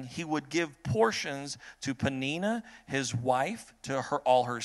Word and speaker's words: Peninnah: And the name of the other was Peninnah. That's how you pronounce that name. Peninnah had children, but it says Peninnah had children, but Hannah had no children.